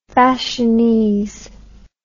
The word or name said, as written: fashionese